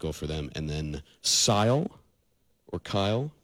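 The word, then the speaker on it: Cyle: It's pronounced like Kyle, of course. Kyle Lahren, the goal for Canada.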